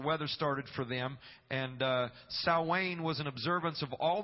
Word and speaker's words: Samhain: It's spelled like Samhain, but it's spoken Samhain.